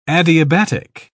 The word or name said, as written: adiabatic